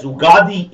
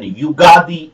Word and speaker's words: Ugadi: So it's pronounced as Ugadi in Andhra Pradesh and Telangana states and Yugadi in Karnataka and Konkani areas.